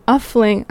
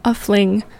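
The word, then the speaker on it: Oeffling: This first set is going to include songs by Tyzo Bloom, Hater, and Tommy Uffling.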